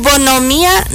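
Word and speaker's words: bonhomía: Su bonomía natural, se pronuncia raro, digo yo, porque es B-O-N-H, intermedia, O-M-I-A, con yato al final, o sea, tilden la I. Bonomía, se pronuncia raro porque tiene esa H ahí intermedia.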